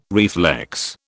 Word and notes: reflex: British English

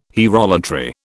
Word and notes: hierolatry: British English

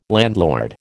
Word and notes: landlord: American English

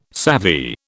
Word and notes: savvy: British English

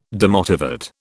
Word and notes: demotivate: British English

